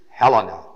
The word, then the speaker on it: Hellen: And here he says in verse 14, to the Greeks, the word for Greek all through the word is the word Helena, H-E-L-L-E-N, long E, last E.